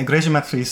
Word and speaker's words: Igreja Matriz: The Igreja Matriz. Am mm-hmm. I saying that right? – Yeah, more or less. Yeah, Igreja Matriz.